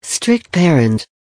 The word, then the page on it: strict parent: American English